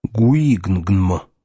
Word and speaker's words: гуигнгнм: Его можно было бы произнести как «гуигнгнм».